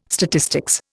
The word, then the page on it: statistics: Indian